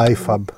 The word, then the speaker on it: IFAB: ΙΦΑΠ λέγεται. Ναι. Νομίζω iFab. Ε, ναι, αυτό. – Ε, α, όχι, ναι. το, επειδή το. Εγώ με την το ε, ε, ελληνική σεραϊκή προφορά το, το είπα.